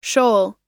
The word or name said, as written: shoal